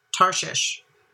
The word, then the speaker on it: Tarshish: Tarshish.